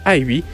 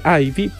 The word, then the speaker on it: IVE: Y de ahí también ahorita vamos a hablar de su nombre, porque Ivy, porque se escribe I-V-E, podríamos decir que sí se pronuncia Ivy,